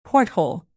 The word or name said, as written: porthole